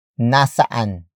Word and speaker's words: nasaan: Nasaan ang sapatos ko? Nasaan ang sapatos ko? The first word is nasaan, which is spelled as N-A-S-A-A-N. Again, it means where, but in a prepositional sense. Followed by ang. Ang. Ang. Ang is spelled as A-N-G.